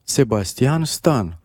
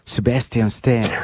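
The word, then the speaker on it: Sebastian Stan: Comment on prononce en roumain son nom? – Sébastien Stan. – Et en anglais? – Sébastien Stan.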